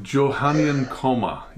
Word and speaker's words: Johannine comma: Is that how you pronounce that, Pastor? – Yeah, it's actually Johannine comma.